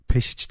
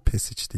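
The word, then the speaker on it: Pešić: Ee, yani şey garip ben biraz peş için Peşic diye okunuyor değil mi? Pesic değil. – Ya aslında öyle evet zaten hı. Sırp ismi oluyor.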